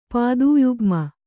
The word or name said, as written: ಪಾದೂಯುಗ್ಮ